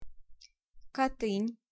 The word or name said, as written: Katyn